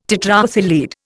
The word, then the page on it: tetraethyl lead: Indian